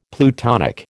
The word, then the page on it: plutonic: American English